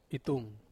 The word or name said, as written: tung